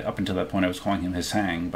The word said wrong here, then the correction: Hsing: but I guess he actually is. People call him Sean.